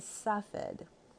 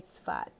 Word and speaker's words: Tzfat: In English, it's also known as Safed, but here in the land we say Sfat. Think of the word Ritz. It ends in TZ, and TZ is a Hebrew letter, and Sfat is spelled TZFAT.